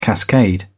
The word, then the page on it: cascade: British English